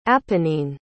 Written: apennine

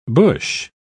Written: bush

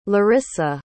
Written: larissa

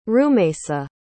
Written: rumaysa

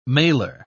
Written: malar

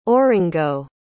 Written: oringo

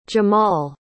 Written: Jemal

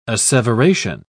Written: asseveration

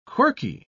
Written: quirky

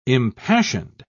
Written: impassioned

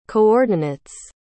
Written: coordinates

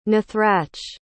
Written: nathrach

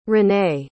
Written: rene

